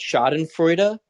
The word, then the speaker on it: Schadenfreude: And I never know how to say this word. I think it's pronounced Schadenfreude. – I think so.